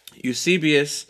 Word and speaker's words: Eusebius: I'm saying Eusebius, but it's Eusebius. Eusebius lived around the late first century, early second, third century.